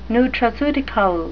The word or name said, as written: nutraceutical